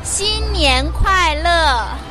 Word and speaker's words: 新年快乐: 新年快乐. – Yeah, say that again. I'm going to try. – 新年快乐. – 新年快乐.